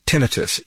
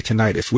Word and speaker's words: tinnitus: You say tinnitus. Which is the correct pronunciation? – Both pronunciations are that it's like President Bush called it nuclear and some people called it nuclear. So take your choice.